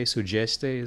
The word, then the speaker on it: Sudeste: And the D is pronounced like a J in mm-hmm. Sudeste, Sugeste, is that? – That's right.